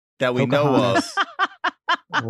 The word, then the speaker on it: Pocahontas: That's not how you say it. – There's a T at the end. – Poke your honest. – Pocahontas.